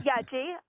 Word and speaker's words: Yeji: Yes, my name is Yeji. Rhymes with veggie, I tell my friends.